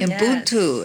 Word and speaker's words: Ubuntu: In an earlier conversation, we talked about, and you're going to have to help me with the pronunciation with this one, Ubuntu. – Very good. – Ubuntu.